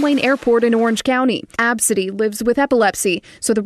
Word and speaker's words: Abcde: This is Absidy, but her name is spelled A-B-C-D-E.